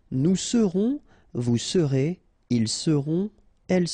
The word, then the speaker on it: seront: Ils seront, final T not pronounced, and then you've got this nasal, on, seront, elles seront.